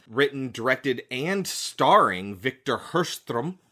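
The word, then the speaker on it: Sjöström: But Hirschström is apparently how you pronounce it. It is S-J-O with an umlaut, S-T-R-O with an umlaut M. So it starts with an S-J and it's pronounced Hirschström. Hirschström.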